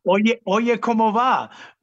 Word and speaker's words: Oye Como Va: Oyo Como Va? – Oye Como Va.